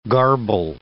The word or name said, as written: garble